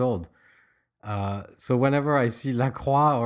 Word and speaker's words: LaCroix: I mean, so it's French, because in French you don't say the X either, but still not French. So it's ambiguous. – The silent X in LaCroix, LaCroix.